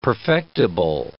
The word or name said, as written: perfectible